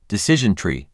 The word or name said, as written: Decision tree